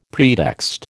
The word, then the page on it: pretext: American English